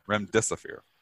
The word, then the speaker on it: Remdesivir: Remdesivir. How do you say this? – Remdesivir.